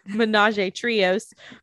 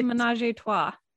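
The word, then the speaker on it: Ménage à Trois: So we didn't do the barefoot, but we did do, which I called it the Ménage Trios, but I have been corrected, that is not how you pronounce it. – Ménage Trois. Like the song.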